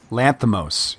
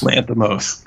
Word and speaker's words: Lanthimos: Did I do that right? – Lanthimos, yes. – Lanthimos.